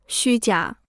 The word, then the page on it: 虚假: xū jiǎ